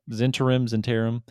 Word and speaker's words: Zhentarim: And he's like, well, since we're on such good terms, I guess I could tell you we are part of the Zentirim, if it means anything to you, just so you know. – Zentirim. How you spell that? – Yeah. Sorry, it's the Zen. Yeah, Zentirim. Zhentarim.